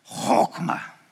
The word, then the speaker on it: chokmah: But this word, when you read it, it's pronounced chokmah. Now, when I did that at 8 o'clock, someone said I sounded like a Klingon. Chokmah. That's how you pronounce it.